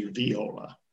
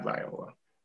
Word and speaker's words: viola: I've been corrected on my pronunciation of the instrument is actually viola, not viola.